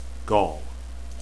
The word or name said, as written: gaul